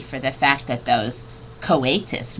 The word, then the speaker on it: coatis: These are coatis, Miss Brisbane said, reading from the letter, pronounced coatis.